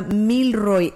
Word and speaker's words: Milroy: Milroy, no, como, haga de cuenta como de mil, el número mil, y después Roy, R-O-Y, sí, pero es una sola palabra, Milroy, M-I-L-R-O-Y,